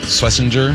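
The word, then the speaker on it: Schwesinger: Carson Schlesinger. Am I saying that right? – Mm-hmm.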